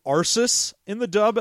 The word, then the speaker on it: Ursus: And Arsus. – And Arsus, both of whom are mispronounced in the dub. It's supposed to be Xenia and Ursus.